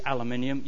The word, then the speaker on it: aluminium: It, by the way, is aluminium. You Yanks mispronounce it.